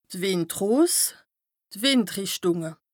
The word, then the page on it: d' Wìndros, d' Wìndrìchtùnge: Bas Rhin; Strasbourg